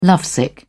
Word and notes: lovesick: British English

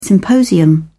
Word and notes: symposium: British English

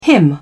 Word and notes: him: British English

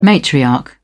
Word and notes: matriarch: British English

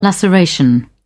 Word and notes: laceration: British English

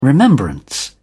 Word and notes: remembrance: British English

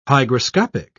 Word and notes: hygroscopic: British English

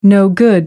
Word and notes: no-good: American English

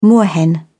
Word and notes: moorhen: British English

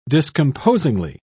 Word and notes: discomposingly: British English

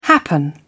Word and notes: happen: British English

